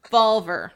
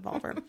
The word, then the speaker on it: Volver: Volver. – That's how you pronounce it. Volver.